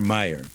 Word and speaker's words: Meijer: There's a moderate Republican named Peter Meyer, M-E-I-J-E-R. You may have seen Meyer stores. I think they're food stores, Meyer. You don't pronounce the J.